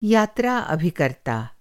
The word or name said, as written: अभिकर्ता